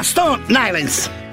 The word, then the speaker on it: Stone Island: Questi jeans della Stone Islands. – Stone Islands. – Ma perché scrivono come si legge? – Si chiama così, Stone Island.